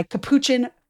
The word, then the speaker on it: Capuchin: at a mass presided over by Capuchin Franciscan friars.